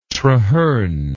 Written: traherne

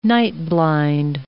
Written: night-blind

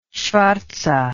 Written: shvartze

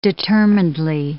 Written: determinedly

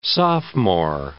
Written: sophomore

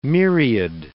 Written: myriad

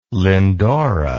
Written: lyndora

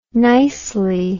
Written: nicely

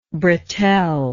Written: bretelle

